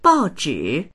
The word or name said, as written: bao4zhi3